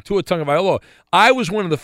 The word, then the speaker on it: Tagovailoa: So I knew right away how to say Tua Tagovailoa.